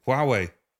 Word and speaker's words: Huawei: So now we see with, I never know how to pronounce it, Highway, is it Huawei? Huawei. Okay,